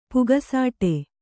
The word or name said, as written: ಪುಕಸಟ್ಟೆ